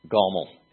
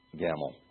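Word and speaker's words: gomel (גמל): It's gomel. Okay, just make it just say camel and make it a G instead of a C or K sound at the beginning. Camel, gamel.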